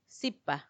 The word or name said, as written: sipa